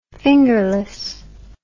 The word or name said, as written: fingerless